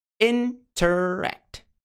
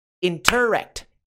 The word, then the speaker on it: interact: Interact. Interact. Interact. Ou é interact. Interact. A sílaba tônica é RET.